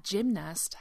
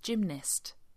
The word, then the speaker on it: gymnast: Now, the word that we use for a person who does gymnastics is usually pronounced gymnast. Gymnast. It sounds like it has an I towards the end, but actually it's spelt with an A.